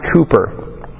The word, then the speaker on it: Cowper: William Cooper wrote this hymn, "There Is a Fountain." You can see at the bottom it says text William and it looks like Cowper, but it's actually pronounced Cooper.